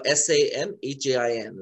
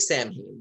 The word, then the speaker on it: Samhain: Uh, S A M H A I N. It looks like Samhain, but it's pronounced Samhain.